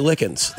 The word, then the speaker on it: Likins: So many people spell my last name L-I-K-E-N-S. That's actual L-I-K-I-N-S. – I feel like it really should be spelled E-N-S, but... – Or if my name was spelled like L-Y-C-H-E-N-S, no one would ever call me Likens. They would know that it's Likens.